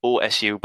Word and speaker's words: OSU: Answering with us, OSU, by the way, long O, silent U,